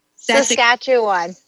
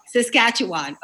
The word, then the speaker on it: Saskatchewan: How do you say that? – Saskatchewan. – Oh, Saskatchewan. Okay.